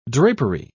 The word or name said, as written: drapery